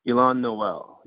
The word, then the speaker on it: Y'lan Noel: Is it Y'lan Noel? – Y'lan Noel, yeah.